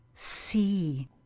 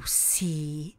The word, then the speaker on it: sea: A slovo moře zní anglicky sea. The sea. Výslovnost je úplně stejná jako u slovesa vidět. To see. Jenom pravopis je jiný.